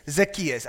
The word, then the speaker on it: Zacchaeus: Is that the pronunciation in English? Zacchaeus. Zacchaeus. All right. We say Zacchaeus. Oh, you say Zacchaeus.